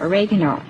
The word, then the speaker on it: oregano: Next ingredient is your spice, and this is an Italian herb, oregano, or as some people pronounce it, oregano.